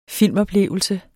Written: filmoplevelse

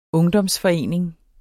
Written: UNGDOMSFORENING

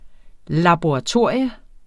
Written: LABORATORIE

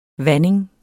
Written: vanding

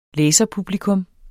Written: læserpublikum

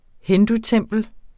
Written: hindutempel

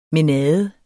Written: mænade

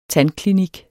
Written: tandklinik

